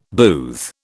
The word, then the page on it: booth: British English